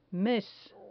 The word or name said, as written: mes